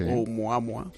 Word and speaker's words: Oumuamua: Estoy sorprendido por tu pronunciación del hawaiano. Sí. Oumuamua. Muy bien, perfecto.